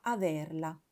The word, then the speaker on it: averla: Si pronuncia averla con la E aperta, come quella che utilizziamo quando pronunciamo la terza persona singolare del verbo essere.